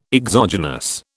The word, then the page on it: exogenous: British English